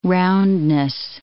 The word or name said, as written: roundness